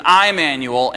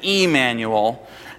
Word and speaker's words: Emmanuel: There's an I-manual and an E-manual. And if you don't pronounce that difference, somehow everybody in Baltimore knows.